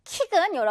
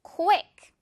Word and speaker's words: quick: 자, 이것도 너무 그냥 퀵 이렇게 해버리기보다는 또는 심지어 킥이라고 말하는 분들 없죠? 킥은 여러분 차는 거죠? 차는 거 말고 우리 이거 쿠 발음 살려줄 거예요. 그래서 퀵. 빠른, 빨리.